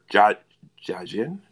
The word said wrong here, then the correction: Daijin: am I saying that right? – Daijin.